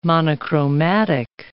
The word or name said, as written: monochromatic